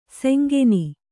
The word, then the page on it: ಸೆಂಗೆನಿ: Kannada